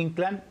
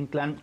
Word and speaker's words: Inclán: Los quiero invitar a que nos sigan ahí a Julio Inclán. Mi nombre completo, nada más que fíjese que es el de Julio Inclán con acento en la A.